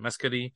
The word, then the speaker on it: Mescudi: Resurrection of Scott Mescudi, how do you pronounce that last name? Mescudi.